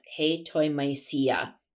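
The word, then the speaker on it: hetoimasia: And for those of you that are, you might maybe want to look that up in your own study of the Bible, it's spelled Hetoimesia, and it's pronounced hetoimesia.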